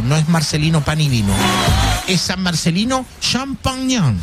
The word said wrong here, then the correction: Champagnat: Champagnon. ¿Champañá? ¿Champañán? ¿Cómo es la cuestión? Champagnon. Champagnat. Ahí está.